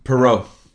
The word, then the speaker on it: Perreault: Uh, per how do you say that name, per? – Perreault. Perreault, yeah. Perreault goes to 64, Zegras 46, and, and Drysdale 34.